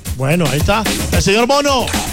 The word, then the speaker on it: Bono: El arquero Bonus tapando. Bonus. – Bono. B-O-N-O, así se escribe.